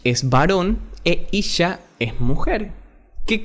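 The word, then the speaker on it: Isha (אישה): Pero mujer se llama o se dice en hebreo Isha. I-S-H-A. Isha. Así se dice mujer en hebreo.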